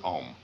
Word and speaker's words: Om: Now, the sound O in Sanskrit is analyzed into two sounds, A and U. So this can be written either O-M or A-U-M, and you'll see it both ways. When one pronounces OM, the sound starts in the back of the mouth, ah, and then it goes, fills the mouth cavity, oo, and then it closes at the lips, m.